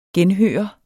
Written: genhøre

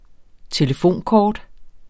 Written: telefonkort

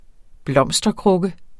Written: blomsterkrukke

